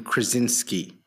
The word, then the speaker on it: Krasinski: I would see John Krasinski.